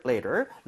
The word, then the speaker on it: later: 물론 이제 영국 쪽에서는 flap이 잘 안 나요. 그래서 later 이런 식으로 발음되겠지만 우리는 만약에 미국식 영어를 한다면 얘를 좀 부드럽게 해주면 더 좋을 것 같아요.